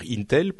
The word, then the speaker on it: Intel: j'ai dit Intel, je voulais dire Intel pour les, ceux qui parlent de prononciation.